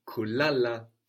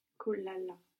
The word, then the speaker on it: kula: You put the accent again on the second last syllable, kula.